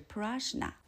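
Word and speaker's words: prashna (प्रज्ञा): 般若的梵文读音是 prashna，翻译过来是大智慧。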